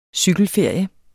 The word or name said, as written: cykelferie